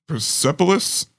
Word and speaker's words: Persepolis: Persepolis Rising? Is that how you say it? – Yeah, that sounds good to me.